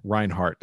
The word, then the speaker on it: Reinhardt: Today on the podcast, we've got Ben Reinhardt.